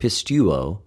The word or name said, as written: πιστευω